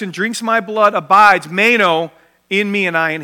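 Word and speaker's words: meno (μένω): It's pronounced meno.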